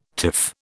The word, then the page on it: tiff: British English